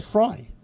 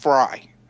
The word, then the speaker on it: Frey: Well, they said Fry. – Yeah, his last name is pronounced Fry. Yeah. But Glenn Frey from the Eagles makes that confusing for me.